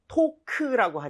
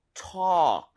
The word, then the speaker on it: talk: L 발음은 당연히 모음이죠, 그렇죠? 오케이, 그래서 발음할 때 근데 talk라고 하지 말아주시고요. – 네. – A의 강세를 주시고 그리고 입 모양을 좀 벌려서 talk보다 오히려 talk 이렇게 talk. – 어에 가깝습니다. 차라리.